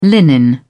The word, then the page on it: linen: British English